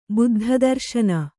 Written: ಬುದ್ಧದರ್ಶನ

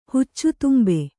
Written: ಹುಚ್ಚುತುಂಬೆ

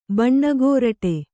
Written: ಬಣ್ಣಗುರುಡಿ

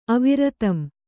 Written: ಅವಿರತಂ

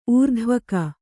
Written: ಊರ್ಧ್ವಗ

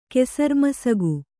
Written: ಕೆಸರ್ಮಸಗು

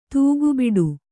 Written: ತೂಗುಬಿಡು

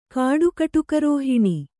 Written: ಕಾಡುಕಟುಕರೋಹಿಣಿ

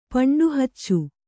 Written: ಪಂಡುಹಚ್ಚು

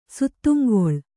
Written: ಸುತ್ತುಂಗೊಳ್

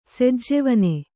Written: ಸೆಜ್ಜೆವನೆ

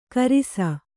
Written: ಖರ್ಸಿ

